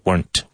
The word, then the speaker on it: weren't: Again, weren't, one syllable, weren't. It should rhyme with burnt.